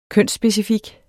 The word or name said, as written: kønsspecifik